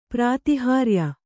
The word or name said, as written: ಪ್ರಾತಿಹಾರ್ಯ